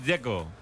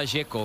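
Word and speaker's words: Džeko: Dzeko. Bruno dice que es Dzeko. – Como tirando a J, Dzeko o algo así.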